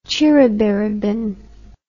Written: ciribiribin

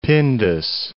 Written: Pindus Mountains

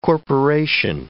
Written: corporation

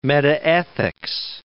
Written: metaethics

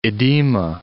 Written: edema

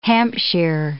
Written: Hampshire

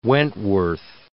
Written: Wentworth

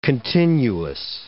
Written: continuous

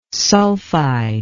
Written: sulfide

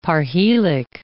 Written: parhelic

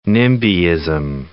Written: NIMBYism

